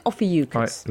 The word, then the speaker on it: Ophiuchus: Because a story was doing the rounds that NASA had unveiled the 13th star sign, Ophiuchus. Is that how you pronounce it? Ophiuchus. – Right, let's stick with that one. That's the safest.